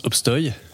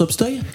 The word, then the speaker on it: Obstoy: Ça t'a fait bizarre que je t'appelle Cédric Obstoy euh, euh, Ouais, parce que déjà ça se prononce Obstoy.